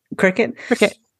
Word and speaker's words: Cricut: Like, are you always going to buy the knitting book or are you going to reach out into, I don't know, like Cricut or some other, is that how you say it? – Yeah. – Cricut.